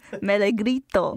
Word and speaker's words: Melegrito: You said Melegrito.